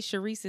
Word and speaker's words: Sherise: It is Tata Sharice, T-A-T-A. Sharice is spelled like she rise, S-H-E. R-I-S-E.